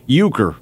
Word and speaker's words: Euchre: It's spelled Euchre, but it's pronounced Euchre. Like, as in Bob Euchre.